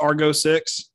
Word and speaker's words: Argo: Argo is it is it pronounced Argo six? – Yeah.